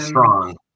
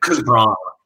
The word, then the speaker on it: Strachan: Is it Strachan? It's like Strong.